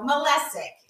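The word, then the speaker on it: Malesic: So Molesic is the proper way to say his last name.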